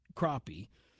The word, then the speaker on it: crappie: So, you know, I'm going to say this because some of the headlines you'll read play off the spelling because it's spelled like crappie, but it's pronounced crappie.